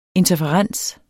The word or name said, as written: interferens